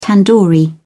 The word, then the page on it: tandoori: British English